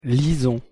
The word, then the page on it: lisons: French